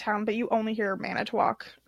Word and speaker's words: Manitowoc: I mean, it's M-A-N-I-T-O-W-O-C, Manitowoc.